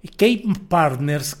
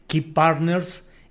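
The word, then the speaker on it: Kei Partners: Key Partners, que aunque le digo se escribe K-E-I, su logotipo es justamente el de una llave, Key Partners.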